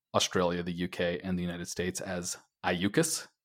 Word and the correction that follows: AUKUS: which for some reason is, for me, the most natural way of reading AUKUS. But I had this sneaking suspicion that it was not, in fact, pronounced AUKUS, and so some YouTubing today before the podcast has corrected me, and it is, in fact, the much more reasonable and much more boring sounding AUKUS.